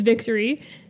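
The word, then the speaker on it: Victorie: And then it took me a minute because my name is Victory, and it's spelled with an IE instead of a Y.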